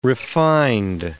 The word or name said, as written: refined